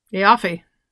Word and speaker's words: Aoife: And the name, I'm gonna spell it for you, and you're gonna tell me how you think to say it. It's spelled A-O-I-F-E. – Eoffe. – Aoife.